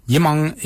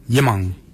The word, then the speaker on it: yimoong: Yimang is spelled Y-I-M-O-O-N-G, yimang.